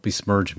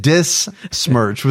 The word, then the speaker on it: besmirch: It's besmirch. – I just said, do not besmirch me. – Now you said dismirch with a D.